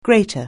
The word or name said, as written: grater